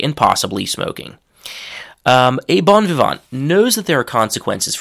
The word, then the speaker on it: bon vivant: So that's the French pronunciation. English pronunciation is bon vivant. So a bon vivant is literally a uh,